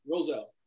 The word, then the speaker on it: Roselle: You're in uh, is it Russell? I want to make sure I say it right. Roselle. Roselle, New Jersey.